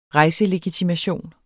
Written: rejselegitimation

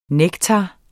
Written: nektar

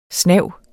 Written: SNAVETS

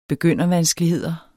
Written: begyndervanskeligheder

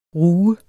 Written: ruge

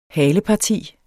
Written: haleparti

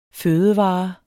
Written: fødevare

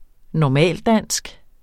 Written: normaldansk

